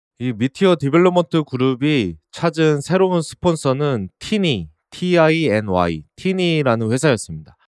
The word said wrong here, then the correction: Tiny: 타이니라고 읽어야 하는데 티니라고 읽었습니다. 타이니로 수정합니다.